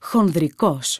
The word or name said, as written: χονδρικός